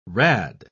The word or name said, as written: rad